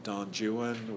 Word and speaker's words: Don Juan: Also, remember, Byron will begin book 3 of Don Juan, which I'm not mispronouncing, with the words, Hail, Muse, etc.